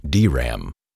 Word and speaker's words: DRAM: The D stands for dynamic memory and is pronounced separately, as in DRAM.